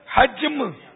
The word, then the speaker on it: حجم: وہ تلفظ ہے حجم, یعنی تورات شریف قرآن مجید سے حجم میں کئی حصے زائد ہیں.